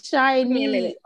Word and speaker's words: SHINee: It's SHINee.